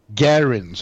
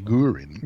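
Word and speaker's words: Guerin: Garin säger man. Nu, nu, ska jag komma ihåg det. Garin. Jag säger Gurin. Gurin, ja det är något annat.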